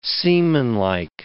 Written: seamanlike

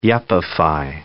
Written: yuppify

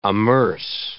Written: amerce

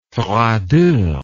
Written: froideur

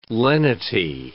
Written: lenity

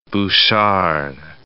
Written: Bouchard

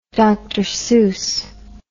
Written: Dr Seuss

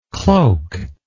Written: cloakless

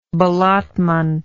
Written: ballottement

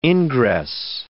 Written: ingress

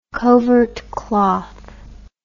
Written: covert cloth

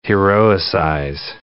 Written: heroicize

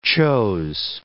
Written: chose

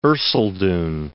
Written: Thomas of Erceldoune

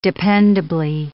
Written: dependably

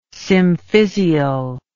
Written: symphysial

